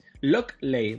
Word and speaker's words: Lockley: Lockley, ¿vale? No es Loki de Loki, sino Lockley.